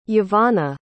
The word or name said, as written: Yavanna